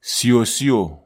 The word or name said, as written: siosio